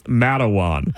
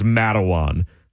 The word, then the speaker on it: Matawan: She's adding letters because she cannot figure out that this grouping of letters is pronounced Matawan.